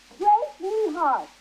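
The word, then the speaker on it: Grace Neihart: Grace Nehart. – How do you spell that? – G-R-A-C-E. – The last name. – Oh, N-E-I-H-A-R-T.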